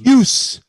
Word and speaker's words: Pius: Is it Pius? I didn't know how you said his first name. – Puce. It looks like Pope Pius, but it's pronounced Puce. He's from Switzerland. – Puce. – P-I-U-S, right? – Cool name. – P-I-U-S, yeah.